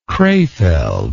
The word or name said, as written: crefeld